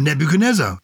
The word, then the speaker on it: Nebuchadnezzar: Oh, Nebuchadnezzar. You can pronounce it. – Okay. – Nebuchadnezzar. – Nebuchadnezzar. – Nebuchadnezzar. – You don't pronounce the D in it then, Nebuchadnezzar.